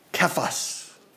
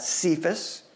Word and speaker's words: Kephas: You shall be called Kephas. Oftentimes we pronounce that Cephas, but in the Greek language it's a K, it's a hard sound.